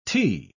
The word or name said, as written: tee